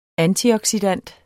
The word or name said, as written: antioxidant